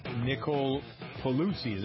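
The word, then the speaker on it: Polizzi: Nicole Pelosi, is that how you pronounce it? – Yes.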